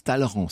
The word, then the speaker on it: Talleyrand: Alors, ça s'écrit Talleyrand et ça se prononce Talleyrand. C'est comme ça, c'est d'habitude.